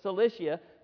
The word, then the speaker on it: Cilicia: I know that's not how you pronounce it in Greek, but that's how we all pronounce it in English. So Cilicia, to strengthen the churches there.